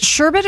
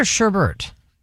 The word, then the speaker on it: sherbet: Is it sherbet or sherbert? – I don't know. I think I've always pronounced it sherbet.